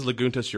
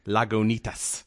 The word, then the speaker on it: Lagunitas: And, yeah, if you pronounce it as Lagunitas, you're wrong. It's according to the label, because they even spell it out like a pronunciation of it. It's Lagunitas. Imperial Red.